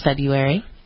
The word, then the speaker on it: February: And when you look it up online, it says the pronunciation is February. Like the first R is silent. – Where did that come from? – Is that not the case? – That is not the case.